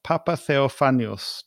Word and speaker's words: Papatheofanous: It's a great pleasure for me to have a conversation with Manos Papatheofanous.